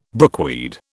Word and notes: brookweed: British English

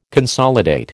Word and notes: consolidate: American English

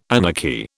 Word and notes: anarchy: British English

